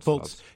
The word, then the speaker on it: Fultz: Fultz.